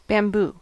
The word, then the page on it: bamboo: American English